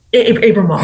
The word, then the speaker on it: Abramoff: And this person, Jack Abramoff, the subject of your second book, Abramoff, is that how you say it, Abramoff? – Abramoff.